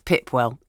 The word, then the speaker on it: Pipewell: Sue suggests, well, it would work if you pronounced it as it's spelt, because there's a village up near Corby called Pipewell, but it's pronounced Pipwell.